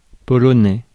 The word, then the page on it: polonais: [pɔ.lɔ.nɛ]; France